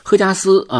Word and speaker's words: 荷加斯: 名字呢叫荷花的荷啊，这个也也在字呃就是发音中也读第四声嘛，所以我就习惯性的读它赫加斯，因为它还有一个名艺名，就是用咱们中国这个姓贺的贺，就是祝贺的贺啊，叫赫加斯，但是我还是用这个荷花的荷啊，但是我读第四声。那么还有一个发音叫霍加斯啊，就是霍元甲的霍。